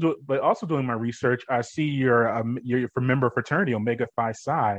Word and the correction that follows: Omega Psi Phi: if I'm saying that correctly. Hope I am. Omega Psi Phi, yes.